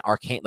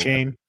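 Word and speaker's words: Achane: I mean, even like an Arcane, like the dude who ran the 4.3, the Arcane, who people. – A-chain.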